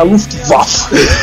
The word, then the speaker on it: Luftwaffe: Então eu fico falando, é a Luftwaffe.